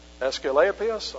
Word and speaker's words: Asclepius: You see, I know how to pronounce that word. Asclepius, or whatever, wherever God was being honored there.